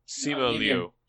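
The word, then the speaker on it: Simu Liu: The movie is more like, I think, the scene they have in the trailer where the actor, is his name Shen Liu? – Sima Liu.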